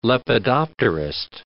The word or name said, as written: lepidopterist